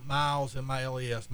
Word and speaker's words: Miles: So it's Tem, T-E-M, Miles, M-I-L-E-S.